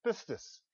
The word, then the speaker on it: pistis: You may not be able to pronounce it. That's okay. Pistis is that word.